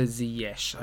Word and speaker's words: Ziyech: And that's right, I did say Ziyech. I believe that's how he pronounces it, not Ziyech or Ziyech. I think it's Ziyech.